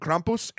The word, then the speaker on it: Krampus: The myth and the legend behind Krampus, and I'm saying it like that because Christoph Waltz on the internet taught me that's how you say it.